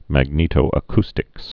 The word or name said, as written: magnetoacoustics